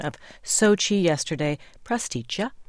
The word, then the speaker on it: Sochi: The name of the city is pronounced not Sochi, but exactly as written. That would be Sochi, just as Mr. Marchenkov writes, chi as in chicken, with the stress on the first syllable, Sochi.